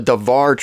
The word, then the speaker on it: Dabhar: But it's called the RIT, which is the writings, but the formal name is the Davar, but it's spelled D-A-B-H-A-R, but I have inside information on pronunciation. It's the Davar translation, and it's a little more consistent.